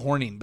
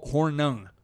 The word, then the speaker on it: Hornung: My dad would always say Paul Horning, but that was not now. It was Paul Hornung. Hornung.